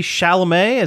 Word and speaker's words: Chalamet: Timothy Chalamet, is that how you pronounce his name? – Yeah.